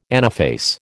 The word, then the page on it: anaphase: American English